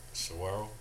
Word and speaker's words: Saguaro: Saguaro? – Mm-hmm.